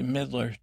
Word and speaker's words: Midler: You say Midler, two syllables. It's got the Midler, M-I-D-L-E-R, right?